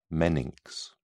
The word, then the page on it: meninx: British English